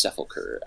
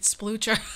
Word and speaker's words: sepulcher: I think it's, I don't know how it was named, but I think it's sepulcher, and it means like a grave or a place of resting, like a resting place. – Oh my god, that makes so much more sense, because I was pronouncing it splucher.